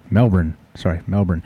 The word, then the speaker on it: Melbourne: In 2015, David Hole, H-O-L-E, was prospecting in Maribor Regional Park near Melbourne, Australia. I said it right. Melbourne.